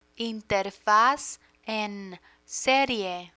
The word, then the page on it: interfaz en serie: Spanish